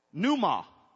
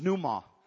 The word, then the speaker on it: pneuma: It means, you don't pronounce the P, but it's called pneuma. That's an N.